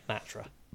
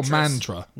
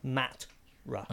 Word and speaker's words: Matra: Is that how you say it? – Yeah, Matra. – Matra or Mantra? – Matra.